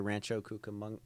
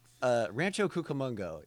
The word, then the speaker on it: Rancho Cucamonga: the section where she was trying to say Rancho Cucamonga? – Yes.